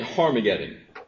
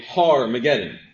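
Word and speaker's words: Har-Magedon: Our scriptures should say Har-Mageddon. This may be the first time that you've ever heard the word pronounced this way, Har-Mageddon. You may have even heard it and say, he's saying it wrong.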